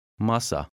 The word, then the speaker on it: Masa: However, number 8 has the stress on the first syllable. Try to read it. – Masa.